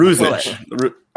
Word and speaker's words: Ruzic: Alexandre Gange, technically Ruzic, because it's like, you know, Eastern European, but Ruzic passes.